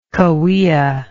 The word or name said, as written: cahuilla